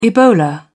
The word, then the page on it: ebola: British English